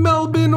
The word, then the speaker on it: Melbourne: Next week, we will get curious about Australian animals for a listener in Melbourne, Australia, and I pronounced it correctly this time.